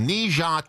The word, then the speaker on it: Ne Zha: But it's actually a film called Ne Zha 2.